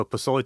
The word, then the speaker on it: pozole: I'm going to say this wrong, maybe pozole? – Pozole. – Pozole. To the people.